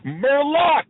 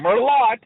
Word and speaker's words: Merlot: He's like, Merlot. I'm like, the T is silent, okay? It's not Merlot, it's Merlot.